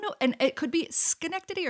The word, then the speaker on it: Schenectady: Schenected. – Oh, Schenectady.